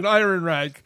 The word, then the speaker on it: Ehrenreich: Einrich? – Say it like it's spelled. – Ehrenreich. Thank you.